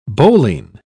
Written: bowline

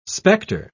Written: specter

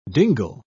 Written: dingle

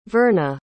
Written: verna

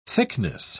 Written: thickness

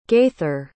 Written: gaither

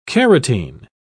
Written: carotene

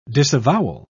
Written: disavowal

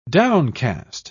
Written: downcast

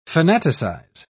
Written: fanaticize

